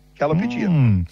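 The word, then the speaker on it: Callipygian: I don't know, but it is pronounced Calla, as in Calla. – C-A-L-L-I. Mm. Calli. Mm. Pigeon. – Calipigian. – P-Y-G-I-A-N. Calipigian.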